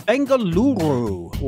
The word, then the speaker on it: Bengaluru: Pleasure to be here, and very well done on pronouncing Bengaluru. I didn't roll the R.